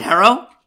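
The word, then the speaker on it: Herro: Is it Hero? – Yes.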